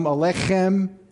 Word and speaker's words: Aleichem: In order to say Aleichem properly, the accent is on the K in Aleichem. All right, it's like a heartier handshake. Let's try that again. Shalom Aleichem.